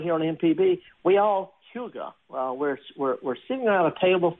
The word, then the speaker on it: hygge: It's a Danish word, and it's got a funny spelling. It's spelled H-Y-G-G-E. H-Y-G-G-E. It's pronounced hygge.